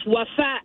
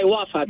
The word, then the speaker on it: Wafa: A lot of people, they used to call me Waffle, Wafa. It's an Arabic name, which means faithfulness. Mm-hmm. And it's pronounced Wafa in Arabic, but I just go by Wafa because it's easier to pronounce.